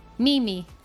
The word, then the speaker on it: Mimi: But yeah, so I try to make a point when I introduce myself to someone new that I say, my name is Mimi. So that accent is on that first I.